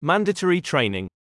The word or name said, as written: mandatory training